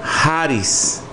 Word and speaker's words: charis: Y la palabra gracia proviene de la palabra en griego charis. ¿Sí? ¿Cómo se dice, hermano? – Charis.